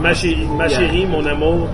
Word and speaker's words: chérie: This is how you say chérie, right? – Chérie. Yeah. – And if you want to sound really French, you have to roll the R's.